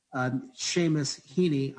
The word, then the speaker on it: Seamus Heaney: You demonstrate what Irish poet Seamus Heaney,